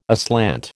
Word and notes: aslant: American English

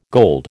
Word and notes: gold: American English

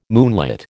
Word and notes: moonlit: American English

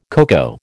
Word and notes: coco: American English

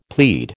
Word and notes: plead: American English

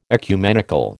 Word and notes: ecumenical: American English

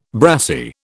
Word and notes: brassie: British English

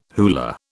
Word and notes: hula: British English

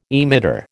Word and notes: emitter: American English

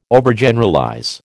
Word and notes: Overgeneralize: American English

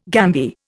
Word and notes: gambier: Indian

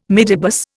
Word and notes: midibus: Indian

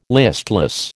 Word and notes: listless: American English

